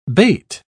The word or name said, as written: bate